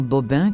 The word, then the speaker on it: Bobin: Sur ma table de chevet, il y avait « Un assassin blanc comme neige » de Christian Bobin, comme ça se prononce, B-O-B-I-N, et « Rouge Brésil » de Jean-Christophe Ruffin.